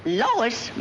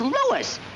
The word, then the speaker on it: Louis: Lois? Me name is Lewis, not Lois. Gee whiz, everybody in Toypel has me name wrong. It's Lewis. L-O-U-I-S.